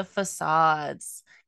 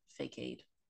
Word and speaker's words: facade: For a long time, I used to pronounce that word fakade. And I'm embarrassed, but I did. did not know the word was pronounced facade.